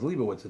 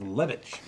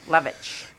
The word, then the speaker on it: Levitch: I thought it was Leibowitz. It's Joseph Levitch. – Levitch. – You love it at Levitch.